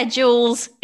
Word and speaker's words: Jules: Yeah, Jules is often.